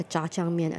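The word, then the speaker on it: Jjajangmyeon (짜장면): Say it one more time slowly. – Jjajangmyeon. – Jjajangmyeon. – Yeah, there you go.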